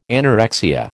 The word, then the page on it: anorexia: American English